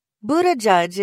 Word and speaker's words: Buttigieg: Buttigieg is probably the best way for English speakers to say Buttigieg's name.